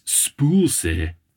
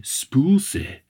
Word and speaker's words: spøgelse: Another correction since recording this episode, I have now looked up the proper pronunciation of the Danish word for ghost, and it is spulse but it's spelled S P O the line through a G E L S E, but it is said Spoolse.